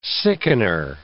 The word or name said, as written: sickener